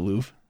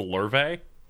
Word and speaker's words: Louvre: I don't want to talk about what happened in the Louvre. – I believe it's pronounced the Louvre.